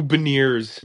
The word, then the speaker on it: Beniers: Who's Bernier? – Oh, it's Matthew Beniers.